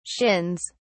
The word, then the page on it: shins: British English